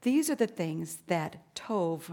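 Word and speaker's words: tov (טוב): It looks like a B on the end, but you pronounce it with a V, tov.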